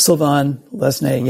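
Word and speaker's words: Sylvain Lesné: The other two characters who loom large are Karen Ash and Sylvain Lesnay, if I'm saying it right. – Sylvain Lesnay, yeah.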